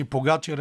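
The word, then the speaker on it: Pogačar: най- извиняй, може би най-интересното нещо в тях беше падането на Погачар а, както си вие също му казвате в Евроспорт, аз бях свикнал да му викам Погачар, но а, разбрах, че Погачар е най-правилно.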